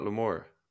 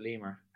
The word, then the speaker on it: Lemur: Chat to me about Lemur. – Well, firstly, I pronounce it Lemur. – Lemur. My bad.